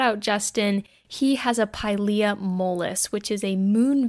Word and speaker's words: Pilea: I was saying it wrong. It's not Pilea, it's Pilea.